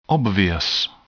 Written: obvious

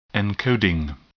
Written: encoding

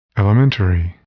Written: elementary

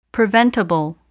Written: preventable

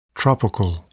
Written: tropical

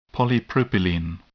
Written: polypropylene

